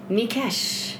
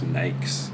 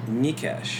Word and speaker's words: Mikesh: Yeah, and I guess my parents thought it was a hard thing to pronounce, or they found when they came to Canada that... So they sort of went with an alternate pronunciation, which is just Nikes.